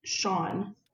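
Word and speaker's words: X Æ A-12: I mean, a lot of people don't know how it's pronounced because the spelling is so odd, but it's actually pronounced, are you ready? It's pronounced Sean.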